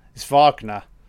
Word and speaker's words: Wagner: See, you said Wagner. The voiceover guy said Wagner. It's Wagner.